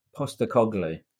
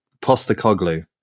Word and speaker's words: Postecoglou: And how do you say his surname? – Postecoglou. – Postecoglou.